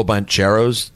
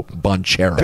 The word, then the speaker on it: Banchero: I think it's Magic, LeBron, and Paolo Banchero. Barely a dookie. – Banchero? – You don't pronounce the H? – I don't think you do. H out of here.